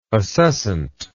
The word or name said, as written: acescent